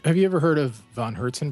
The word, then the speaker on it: Von Hertzen: V-O-N-H-E-R-T-Z-E-N Brothers, Von Herzen Brothers.